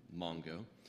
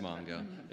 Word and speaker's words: Mango: So we do serve at the Hospital of Hope in northern Togo in a little town called Mango, or Mongo. It's spelled Mango, but it's pronounced Mongo.